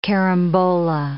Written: carambola

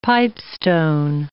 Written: pipestone

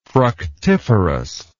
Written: fructiferously

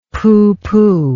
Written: pooh pooh

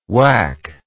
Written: waac

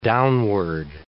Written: downward

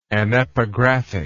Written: anepigraphic

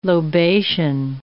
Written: lobation

